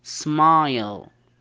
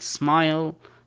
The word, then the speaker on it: smile: مثال عليه smile، ما تنطقها smil، تنطقها smile.